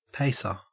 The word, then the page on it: pacer: Australian English